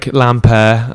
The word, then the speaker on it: Lampaert: So that's like how I phonetically pronounce it, but it's L A M P A E R T.